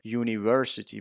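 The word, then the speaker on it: university: یا کلمه یونیورسیتی، خب یو جز این 5 تا حرف بود و خب ما باید قاعدتاً از ان استفاده می‌کردیم، ولی اینجا گفتیم ا یونیورسیتی و درست هم هست. چرا این اتفاق می‌افته؟ چون تلفظه که مهمه.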